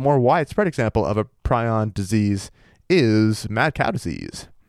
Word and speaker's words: prion: Prion, right. – Prion. I never heard how to say it. Prions. – Prion.